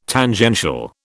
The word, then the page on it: Tangential: British English